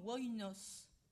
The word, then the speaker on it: oinos: Donc, en fait, le vin en grec, cela se prononçait initialement oinos.